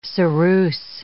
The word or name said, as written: ceruse